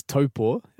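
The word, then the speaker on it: Taupō: So you're from, is it Taupo? Have I pronounced that correctly? – Close. It was pretty good. I lived there. It's Taupo.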